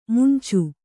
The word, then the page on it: ಮಂಚ: kannada